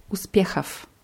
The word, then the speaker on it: успехов: Ja, und Genitiv Plural ist Uspechav. – Uspechov. – Ja, mit O, W am Ende. Das W am Ende wird aber wie F ausgesprochen.